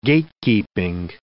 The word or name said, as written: gatekeeping